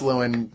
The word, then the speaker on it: Llewyn: It's pronounced Llewyn.